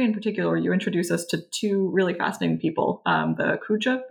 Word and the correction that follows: Khoja: brothers. Is that right, roughly, the pronunciation? – Yeah. – Okay. – Yeah. – Pardon? – I would say Khoja.